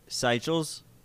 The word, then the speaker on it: Seychelles: How do we pronounce it right? – Somebody, Seychelles.